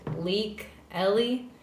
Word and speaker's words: Leikeli: Her name is hard to pronounce. It's Leik Eli, L-E-I-K-E-L-I, and she's great.